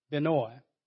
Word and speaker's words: Benoni: And it's pronounced different ways, but it's like Illinois, so it's like Benoni.